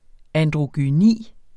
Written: androgyni

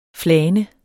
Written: flane

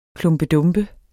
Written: klumpedumpe

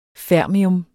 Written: fermium

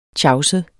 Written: tjavset

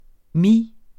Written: mis

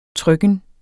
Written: trykken

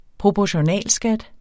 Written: proportionalskat

